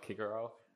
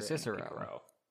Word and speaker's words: Cicero: I recently learned Kikero. That. And I was thinking, wow, I never knew that. – Well, that's probably how we can't know for sure, that's probably how they pronounced it. – Yeah, as opposed to Cicero.